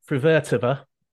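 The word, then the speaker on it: Frühvirtová: The other player who caught my eye was Linda Frivertova. I think I got that pronunciation. Okay. – Yep. – I actually Googled that pronunciation, so I know you did it right.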